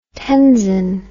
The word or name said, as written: tenzon